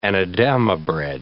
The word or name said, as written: anadama bread